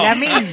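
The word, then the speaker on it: Lamine: Eso es un llamado para Yamin. – Claro. – ¿Lo dije bien? – Sí. – No. – Lamin.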